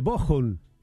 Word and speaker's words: Bochum: De vuelta Raúl desde Bochum, y me dice, bien pronunciado, con CH, entre dos vocales se pronuncia como J. ¿Vio que hablo alemán? – Bueno.